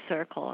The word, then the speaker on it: Circul: It's called CIRCLE, and it's spelled C-I-R-C-U-L.